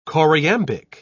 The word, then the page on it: choriambic: British English